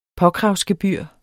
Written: påkravsgebyr

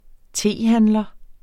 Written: tehandler

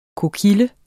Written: kokille

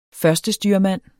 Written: førstestyrmand

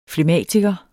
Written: flegmatiker

